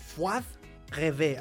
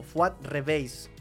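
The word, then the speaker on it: Fuad Reveiz: Fuad Reve, así se pronuncia, colombiano. Fuad Reveis. Fuad Reveis, así, así literal. Fuad Reveis, ok. Pensé que era como medio francés, ¿no?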